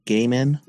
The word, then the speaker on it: Gaiman: Is that how you pronounce it? – Neil. I think it's pronounced Neil Gaiman.